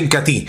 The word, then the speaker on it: Incatea: Desde el Incatí, se escribe Incatea, pero se pronuncia Incatí.